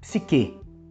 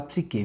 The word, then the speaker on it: psique: é denominada como psique ou psique. Eu prefiro falar psique, viu gente?